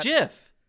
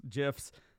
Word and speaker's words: GIF: and some people were calling it GIF until the creator of GIFs, GIFs said it's a soft J.